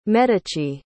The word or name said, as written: medici